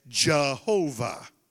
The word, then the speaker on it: Jehovah: But in the English language, over the years, as language changed, in the English, we wouldn't say Y-H-W-H. We would say J-H-V-H, and we would insert the vowel sounds, and that's why you say Jehovah.